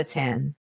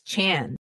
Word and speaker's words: chan: And the internet forums affectionately nicknamed her Nevada Tan. Nevada for the hoodie, and tan being the way a kid would mispronounce the suffix Chan, which they use a lot.